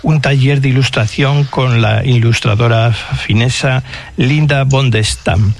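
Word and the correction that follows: Bondestam: Y Linda von der Stam, esa O se pronuncia como una U, Bundestam.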